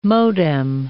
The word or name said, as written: modem